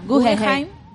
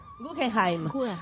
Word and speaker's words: Guggenheim: y la académica recibió el John Simmons Guggenheim, no, así se escribe, Guggenheim. – Guggenheim. Guggenheim. Fellowship, que ha sido entregada a solo tres historiadores chilenos.